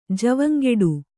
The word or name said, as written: ಜವಂಗೆಡು